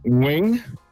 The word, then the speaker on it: Nguyen: Jamen, jeg har et meget klassisk vietnamesisk efternavn, så jeg udtaler som Nguyen.